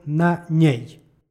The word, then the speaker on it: na njej: Kada ispred zamenice stoji predlog, zamenici se dodaje "n" koje se obavezno jotuje. Dakle, izgovara se "nj", stoga izgovor "na njej".